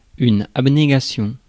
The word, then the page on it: abnégation: [ab.ne.ɡa.sjɔ̃]; France